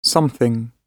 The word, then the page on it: something: British English